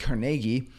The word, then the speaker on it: Carnegie: I first heard about Carnegie Hall, and then I later realized it's pronounced Carnegie.